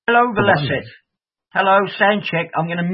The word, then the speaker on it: Velocet: I was going to listen to, do I say it, Velocet? – Velocet's the correct pronunciation.